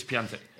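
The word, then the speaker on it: Piątek: I don't know how the actual pronunciation of his name, but he was Piatek.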